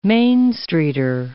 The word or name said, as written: Main Streeter